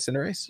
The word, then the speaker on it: Cinderace: Did I say that right? Cinderace? – Yeah, you got it. Yeah, that's right.